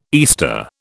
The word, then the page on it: Easter: British English